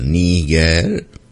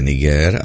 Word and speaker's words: Niger: Podle pravidel ruského jazyka to vždy byl Níger. A také to tak zůstalo. Níger. Šach Nazarov dál vyslovuje Niger a to znamená jen jedno, že dál drží ten styčený prst v kapse a to jeho vlastenectví je jen vnější obal.